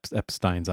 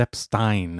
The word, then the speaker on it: Epstein: It's Epstein, not Epstein. – Epstein. – Everybody calls him Epstein. It's E-I, so it's Stein. That's how that works.